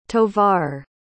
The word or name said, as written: tovar